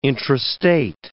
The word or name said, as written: intrastate